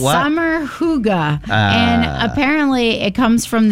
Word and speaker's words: hygge: Hooga. Hooga. Hooga. – But it's spelled Hooga. – H-Y-G-G-E. – Hooga.